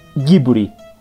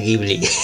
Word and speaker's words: Ghibli: Sí, eh, como decías hace rato, pues sí, Pixar e-, es, grande, pero me parece que Ghibli, eh, que creo que está mal, mal pronunciado antes de que nos empiecen a cuchillar, me parece que se pronuncia Ghibli.